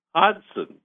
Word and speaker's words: Hodgson: And he said, well, it's actually Hodgson. The G is silent.